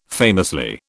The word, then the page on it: famously: British English